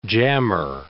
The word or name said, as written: jammer